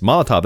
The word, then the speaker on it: Molotov: Yes, Molotov. That's how I would pronounce it.